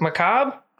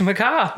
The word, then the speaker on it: macabre: How do you say it? Macrobe? – Macrobe? – Mm-hmm. – Macabre? – Macabre. – Yeah. – There's an R in there. – It's macabre. Macrobe. – Macabre. That's not how macabre should be spelled.